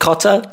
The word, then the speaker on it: cotta: What is the first word you're saying? – Cotta. – Cotter? – Cotta. – You spell it. – C-O-T-T-A. Cotta. – Cotta, not cotter.